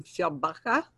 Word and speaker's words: Fjällbacka: I'm going to try to pronounce this. She coached me, but I'll probably blow it. In Fjällbacka with Erica Falk and Patrick Hedström. Do I have that right? Is it? Excellent.